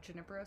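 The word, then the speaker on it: Junípero: Can I tell you that I, up until meeting you, always said Junipero Serra? – Junipero? – Yeah. – Yeah, it's a soft J, like yogging.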